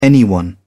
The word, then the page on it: anyone: British English